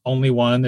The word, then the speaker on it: Onlywon: Only One is my hip hop name. It's spelled O-N-L-Y-W-O-N.